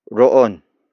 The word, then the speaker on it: roon: The next word is roon. Roon. Roon. Roon is spelled as R-O-O-N.